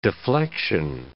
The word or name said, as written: deflection